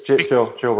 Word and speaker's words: Gill: It's Jill, by the way. – Okay, that's how you pronounce it, Jill.